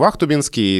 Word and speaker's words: Ахтубинск: Я вот живу в Ахтубинске, и там уже хочется сказать с ударением на «у», потому что это неудобно.